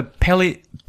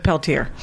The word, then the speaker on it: Peltier: Yeah, I may not have a Pelletier. – Pelletier? – Pelletier. – It's Leonard Peltier. But he pronounces it Peltier.